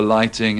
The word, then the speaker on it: lighting: In these sentences, don't pronounce the GH in light and lighting. The speakers in the first group are English. – Where's the light switch? – Eric did all the lighting in our house.